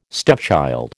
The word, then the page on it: stepchild: American English